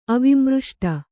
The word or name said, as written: ಅವಿಮೃಷ್ಟ